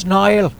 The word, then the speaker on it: Niall: Is it Neil pronounced weirdly? – Yeah, maybe it's not spelled N-I-L-E. Maybe it's Nahimahanaher or whatever Gaelic would be. And he says it's Niall. Maybe, who knows, with his thick accent, maybe it's N-A-I-L.